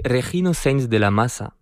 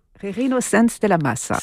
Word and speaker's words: Regino Sainz de la Maza: C'est Regino Sainz de la Maza. – Regino Sainz de la Maza. – C'est ça. Alors la castillane, ce serait de la Maza, mais bon. Il y a toujours des petites nuances.